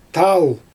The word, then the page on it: taal: Xyzyl